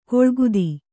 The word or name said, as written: ಕೂಳ್ಗುದಿ